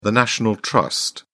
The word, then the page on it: national trust: British English